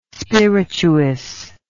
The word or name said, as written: spirituous